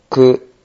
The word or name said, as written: ku